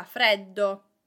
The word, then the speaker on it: freddo: In inverno fa freddo. In inverno fa freddo. Pronunciation here, small tip. Freddo, freddo. It's a longer sound, double consonant. It is not freddo, it is freddo.